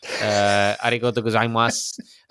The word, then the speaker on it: arigato gozaimasu: arigato gozaimasu. That's my Japanese right there. – You actually pronounced it pretty decent, I have to say.